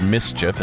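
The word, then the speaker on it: MSCHF: An art collective called, I believe it's pronounced Mischief. It's M-S-C-H-F.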